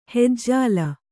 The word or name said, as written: ಹಿಜ್ಜಲ